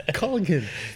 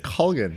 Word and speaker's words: Colgan: We're going to change everyone. It's Colgan. Guys, did you hear that? It's Colgan.